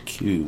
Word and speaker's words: queue: when you have something in the print queue, the Q-U-E-U-E. – Yes. – Q.